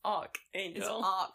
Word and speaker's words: Archangel: It's actually Archangel. – It's spelled arch like a doorway. – Like a doorway, but it's arch as in the ark that Noah built.